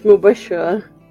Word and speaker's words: Mubashra: It's Mubashra.